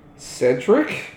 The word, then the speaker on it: Cedric: Segrid? – Cedric? – I was combining him with Hagrid.